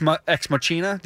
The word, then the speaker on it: Machina: Just work the H in there. It's a soft C and it's an Machina. – H. Come on. What's so hard about Machina.